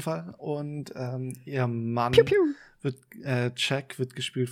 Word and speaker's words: Pugh: die heißt Florence Pugh, also wie Pugh, so wird das ausgesprochen. Pew, Pew.